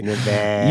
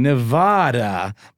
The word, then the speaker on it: Nevada: You have been saying Nevada, but it's really Nevada. Wait a minute. That doesn't make any sense. – What did he capitalize? – He just put the emphasis on the duh instead of the va.